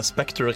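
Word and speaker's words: Spectre: Kan jeg få høre deg uttale Spekter riktig én gang i denne sendingen? Spekter? Takk.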